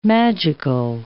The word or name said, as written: magical